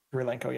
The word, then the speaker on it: Kurylenko: Kurylenko, yes, thank you.